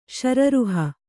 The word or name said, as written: ಶಿರೋರುಹ